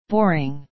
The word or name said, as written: boring